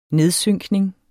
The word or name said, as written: nedsynkning